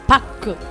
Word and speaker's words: Park: La R de Park. – La R. ¿Qué dije? ¿La K? No, sí. La R. – Ah, o sea que es Pak. – Pak.